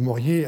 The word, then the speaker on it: Morier: M-O-R-I-E-R, e je vais le prononcer à la française maintenant, Morier, que l'on connaît par ailleurs très bien, dans la mesure où Maurier